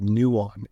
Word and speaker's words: Nuon: The first coin that's doing it that I know of is called Nuon, and that's spelled N-U-O-N. – N-U-O-N. Almost sounds like Yulong.